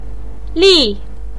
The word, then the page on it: li4: pinyin